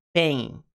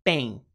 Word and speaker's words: pain: Eles soltam um arzão assim quando falam o P, tá? Isso é muito comum, ok? Por exemplo, a palavra dor, pain, pain. Normalmente, americanos não fazem essa pronúncia seca. Pain, pain, pain. Eles jogam um ar.